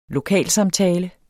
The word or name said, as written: lokalsamtale